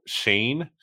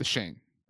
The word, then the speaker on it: Schoen: The name thing is throwing me off because apparently it's Joe Shane, but okay. There's an O-E in his last name. – It's spelled S-C-H-O-E-N. That's how it's spelled. Yeah. The Shane. Right.